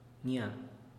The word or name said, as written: nia